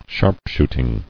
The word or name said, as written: Sharpshooting